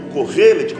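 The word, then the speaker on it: correlê (קהלת): A literatura hebraica o chama de Correlê ou Correlet, como você queira pronunciar, mas Correlê ou Eclesiastes significa ah, o pregador aquele que está à frente da assembleia, o orador na assembleia.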